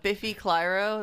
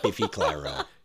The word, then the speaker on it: Biffy Clyro: Oh, Biffy Clyro, that's how you say it. I've never said it out loud or heard it.